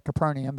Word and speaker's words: Capernaum: After a while, Yeshua returned to Capernaum. You may have heard that said Capernaum, but that's how you say it in Hebrew.